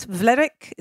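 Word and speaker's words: Vlerick: And then went on to study a master's in general management at Vlerk. Is that correct pronunciation? – Correct, yeah.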